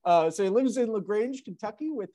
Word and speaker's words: LaGrange: He's a graduate of the University of Louisville and lives in, how do you say that? LaGrange? – LaGrange, yeah.